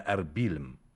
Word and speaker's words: أربيل: ففي حوالي عام 2000 قبل الميلاد، في عهد السومريين، ورد اسمها أوربيلم، ثم حرف الاسم إلى أربيلم، وسماها الإغريق أربيلا، وسماها العرب أربل بدون ياء. أما اليوم فتنطق أربيل بإضافة الياء بعد الباء.